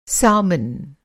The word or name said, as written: सैल्मन